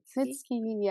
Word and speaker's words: Mitski: Okay, my song of the week is by, I don't know how to say their name, but I think it's Mitski. – Mitski, yeah. – Yeah, that's how you say it.